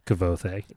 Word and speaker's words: Kvothe: Kvothe. – Me too. Yeah, me too. Yeah. Kvothe.